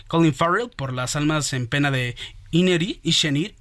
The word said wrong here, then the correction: Inisherin: Ine Inisherin.